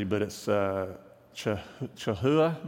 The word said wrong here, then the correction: challah: maybe? Bread? What? Challah. Thank you. I knew I messed that up.